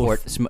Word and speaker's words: Howth: Yeah, Howth was very nice. We went to the small Hoth. – Port. – Hoth. You forget pronunciation so fast.